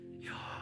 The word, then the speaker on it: Yahweh: It's interesting to me that one of the first names of God, Yahweh, Jewish scholars don't pronounce it, they breathe the name. It's an inhale, Yahweh, and an exhale.